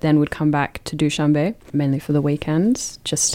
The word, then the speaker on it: Dushanbe: Were you in, is it Dushanbe? Is that how you pronounce the capital? – Yeah, Dushanbe.